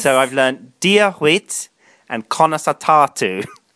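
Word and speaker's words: Dia duit: How do you say that again, Daisy? – Diaguit. – Diaguit. Is that how you say it? – Diaguit. – Diaguit. – Yes.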